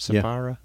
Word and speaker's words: Sapphira: Did I say that right? Sapphira? – Yeah. – I said it right.